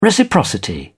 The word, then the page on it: reciprocity: British English